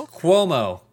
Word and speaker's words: Cuomo: Cuomo. It's Cuomo.